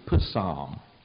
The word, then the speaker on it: Psalm: In South Africa, they pronounce it Psalm. Psalm. How's that? They pronounce the P.